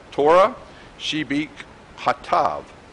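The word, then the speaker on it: Torah Shebik Hatav (תורה שבכתב): The words that Moses finally committed to the writing of the Torah scroll, which the Hebrew for Torah scroll is Torah Shebi'ah, I'm sorry, I butchered that, Torah Shebik Hatav.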